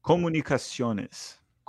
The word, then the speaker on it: Comunicaciones: Comunicaciones.